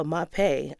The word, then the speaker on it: Mapei: Mapei, is that how you pronounce it? – I think so. Mapei, M-A-P-E-I.